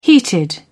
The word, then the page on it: heated: British English